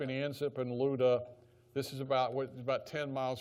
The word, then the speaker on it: Lydda: It looks like Lydda, but it's actually pronounced Luda and Joppa.